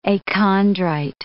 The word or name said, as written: achondrite